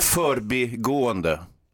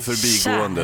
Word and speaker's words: förbigående: Men- Förbigående är det korrekta uttalet, inte förbigående.